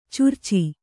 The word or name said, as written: ಚಿರಚ